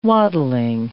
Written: wattling

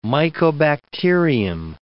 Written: mycobacterium

